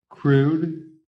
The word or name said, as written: CRUDE